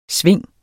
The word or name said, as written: sving